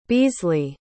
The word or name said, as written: beasley